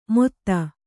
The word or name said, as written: ಮೊತ್ತ